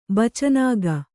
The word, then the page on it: ಬಚನಾಗ: bacanāga